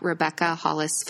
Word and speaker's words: Rebecca Hollis: So my photography handle for Instagram is at Rebecca Hollis Photography.